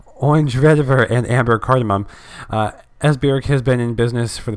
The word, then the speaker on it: Esbjerg: Esbjerg. So basically, okay. Silent B. Esbjerg.